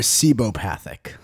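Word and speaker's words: cibopathic: He's sibiopathic. – Sibio. – It's spelled like C-I-B. – Or I'm sorry, sibopathic.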